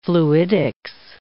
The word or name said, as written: fluidics